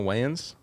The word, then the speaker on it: Wayans: You gotta say his name right, bro. – Marlon Wayne. – Oh, is that right? – Is that right? – Yeah. – Okay. – What did you think it was? – Marlon Wayans. – Is it Wayans? – I think it's Wayans, bro. There's an S at the end. Pretty sure. – No, oh, that sounds. Wayans, yeah. – It is Wayans.